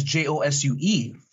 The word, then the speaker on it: Josue: They could not pronounce my name. So it's more like Josh Falaise. I became embarrassed to the point where I didn't even write my name as Josue for up until after high school.